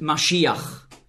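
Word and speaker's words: Maschiach (מָשִׁיחַ): In meiner Bibel steht Messias, weil im Grundtext hier das Wort Maschiach, so ist die hebräische Aussprache, zu finden ist. Messias ist eine sprachliche Anpassung ans Griechische. Nicht wahr? Im Griechischen konnte man den Sch-Laut nicht aussprechen. Und da war es also nicht möglich für Griechen, einfach so Maschiach zu sagen und schon gar nicht zu schreiben, weil im Griechischen gibt es keinen Sch-Laut. Und so hat man den Begriff Messias geprägt.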